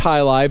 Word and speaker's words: jai alai: It's pronounced high lie, but it's spelled J-A-I-A-L-A-I. – Yeah. – High lie. – I spell it S-E-X.